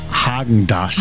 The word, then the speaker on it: Häagen-Dazs: Okay, spricht man das mit R oder mit A aus? – Man spricht es mit A aus. Ich sage auch, es heißt Häagen-Dazs.